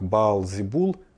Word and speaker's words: Баал-Зебул: Чего только стоит небезызвестный демон в иудейском пантеоне демонов Вельзевул, который исходно произносится как Баал-Зебул, повелитель мух.